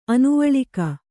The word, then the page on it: ಅನುವಳಿಕ: Kannada